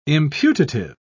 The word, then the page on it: imputative: British English